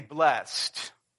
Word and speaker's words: blessed: We would say blessed, right, without putting that extra emphasis on the last syllable, blessed.